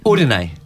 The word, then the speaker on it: Udine: Udine.